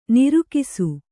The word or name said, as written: ನೊರಕಿಸು